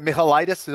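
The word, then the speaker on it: Michailidis: Yeah, listen, I think that, you know, the Greek fighter there, Michalidis, am I saying that right? – Yeah.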